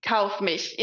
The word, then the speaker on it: Kaufmich: Did I say that right? Kaufmich? – Kaufmich. Yeah, it's tough to pronounce it if you're not German.